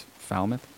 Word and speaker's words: Falmouth: I've been saying Falmouth, but it's foul, almost like foul mouth, but it's Falmouth.